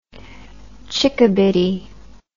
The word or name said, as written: chickabiddy